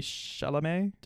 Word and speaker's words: Chalamet: Timothy Chalamet.